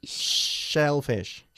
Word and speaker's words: shellfish: Okay, ¿cómo se dice mariscos? – Selfish. – Shellfish. – Shellfish. – ¿Y egoísta? – Selfish. – Selfish, sin la H.